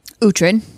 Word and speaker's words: Uhtred: Uhtred.